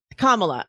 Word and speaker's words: Kamala: Kamala? – Like the vice president? – Yes. How do I say her name? – Oh, Kamala. – Kamala. I always get it wrong when I say this person's account.